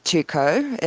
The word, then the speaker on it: Tuco: It's actually pronounced Tuco. It used to have a silent CK at the end, but, you know, people won't pronounce the silent CK.